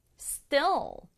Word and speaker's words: still: Still, still, still은 여전히, 아직 이런 뜻으로 문장에 붙일 수 있는데 중요한 건 이걸 still 이렇게 길게 발음하면 안 된다는 거예요. 여기서의 I의 소리는 에, 에, 에 거든요. 그래서 still 하지 말고 still. Still 이렇게 발음해 주세요.